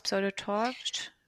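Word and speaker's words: Pseudo-TORCH: Sie haben, Ensa hat ja das Pseudotor, wie spricht man es aus? Pseudotorsch? – Genau, Pseudotorsch-Syndrom.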